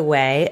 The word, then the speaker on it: Ouai: It's the Way, O-U-A-I.